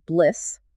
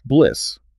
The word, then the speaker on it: BLSS: The acronym is BLSS, pronounced BLISS, buy limits and sell stops. – BLISS.